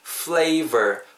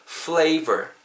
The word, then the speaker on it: flavor: 그리고 flavor 발음하실 때 진짜 주의하셔야 됩니다 F L A V O R입니다 F 사운드 나오고요. V 사운드 나오고 있어요. 이 둘 다 우리가 양입술 다면안 되는 사운드죠. 그게 플레이버가 안 된다는 뜻이에요. full, full. 그때 끌으시고요. flavor, e, ver 발음하실 때도 버가 아니고 ver. 버는 입술이 닿아서 B 사운드가 나는 거고요. – ver, 약간 입술이 떨려야 돼요.